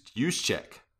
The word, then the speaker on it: Juszczyk: It's Juszczyk. – Oh, Juszczyk. Whatever.